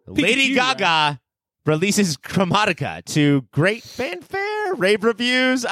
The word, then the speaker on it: Chromatica: Chromatica.